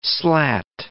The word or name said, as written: slat